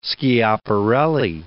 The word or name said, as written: Schiaparelli